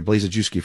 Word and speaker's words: Blazejewski: I've been calling him Blazejewski or Blazejewski for a long time. It's Blazejewski.